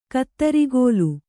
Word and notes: ಕುದುರೆಗಾಲ್: Kannada

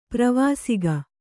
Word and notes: ಪ್ರವಾಸಿಗ: pravāsiga

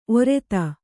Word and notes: ಒರತೆ: orate

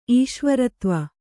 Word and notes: ಈಶ್ವರತ್ವ: Kannada

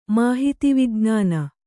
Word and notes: ಮಾಹಿತಿವಿಜ್ಞಾನ: māhiti vijñāna; Kannada

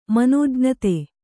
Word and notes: ಮನೋಜ್ಞತೆ: Kannada

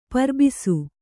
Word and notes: ಪರಿಭಾಸಿ: pari bhāsi

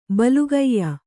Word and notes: ಬಲಗೆಯ್: balagey

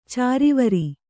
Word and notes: ಚಿರಿವೇರು: cirivēru

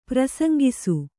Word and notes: ಪ್ರಸಂಗಿಸು: prasaŋgisu

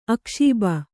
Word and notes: ಅಕ್ಷೋಭ: akṣōbha

